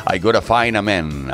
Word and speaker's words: I Gotta Find a Man: como por ejemplo Hazel Dean con ese searching I Gotta Mine, eh, lo he dicho mal, I Gotta Find a Man, ahora sí, eh, 130 BPMs de energía total.